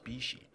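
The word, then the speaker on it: Bixi: And then apparently, cheers to the guys at Paulie G's pizza joint a block away, because we were calling it Bixie, and he's like, well, we went on the soft open, and it's actually pronounced Bishi.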